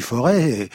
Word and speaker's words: Forez: Forez, enfin non, pardon, le Forêt, sinon je vais me faire, le Forêt, on ne prononce pas le Z final, le Mimi, bouilli de farine au lait.